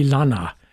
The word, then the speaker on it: Lana: And the setting is by the Spanish-Mexican composer Miguel Mateo Dayo y Lana. That's the last name is spelled L-A-N-A, who worked in the second half of the 17th century at the cathedral in Puebla, Mexico.